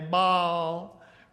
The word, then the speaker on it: Baal: B-A-A-L. We say what? Baal. A little drawl in there, Baal, right? But the 2 A's in the middle divide that into 2 consonants. And so it's Baal, right? 2 syllables, all right?